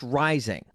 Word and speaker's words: Reising: And his last name may look like it's pronounced Reising, but it's actually pronounced Rising. Buck Rising. His name loosely translates to inflation. In fact, a good way to remember how to pronounce his last name is that his last name is a lot like his weight, Rising.